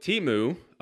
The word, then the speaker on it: Temu: So the example that he puts in the book of shopping, I believe, is the app Temu. Is that an app? T-E-M-U? Am I pronouncing that right? Okay, Temu.